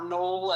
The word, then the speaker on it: Noel: called Noel, or Noel as it's pronounced in the story.